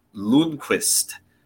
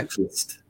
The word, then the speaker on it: Lundqvist: The actual pronunciation is Nils Lundqvist. – Okay. Lundqvist.